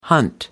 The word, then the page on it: hunt: American English